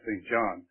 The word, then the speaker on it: St. John: His last name is spelled S-T-J-O-H-N. Most people call it St. John, but it really is St. John.